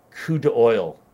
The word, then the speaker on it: coup d'oeil: coup d'oeil. It's like Trump to oil, but the word coup.